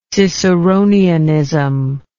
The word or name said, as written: ciceronianism